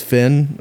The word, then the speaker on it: Finn: So I started releasing music under the name Finn, but just F-I-N. And that was my name now, my actual name is Finn, with spelled with two N's.